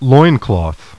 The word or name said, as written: LOIN